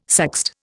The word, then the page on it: sext: Indian